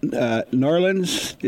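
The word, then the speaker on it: New Orleans: It's like N-A-W, you're like Narlands, N-A-W-L-O-I-N-S, however you want to pronounce it. Yeah, down to law. It's all one word.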